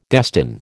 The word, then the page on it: destine: American English